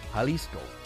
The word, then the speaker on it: Jalisco: J is pronounced H. I know, I'm out of practice. Jalisco.